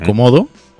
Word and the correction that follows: kodomo: Codomo. Codomo, perdón. Codomo. Codomo, ¿eh? no, es dragón de Komodo.